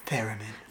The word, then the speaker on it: theremin: Theremin.